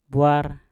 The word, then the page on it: bwar: Mpur